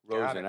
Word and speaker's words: Rosen: Is it Rosen? – Rosen? Yep, the Rosen. – Rosen. I've said it right. Okay. I was saying it wrong for a long time.